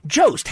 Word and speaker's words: Jost: Jost, how it's spelled. J-O-S-T, Jost.